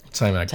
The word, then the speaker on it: Taimak: Yeah, I think it's pronounced Taimak. Taimak? – Yeah. – Taimak. That's a cool name.